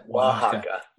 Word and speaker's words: Oaxaca: Oaxaca.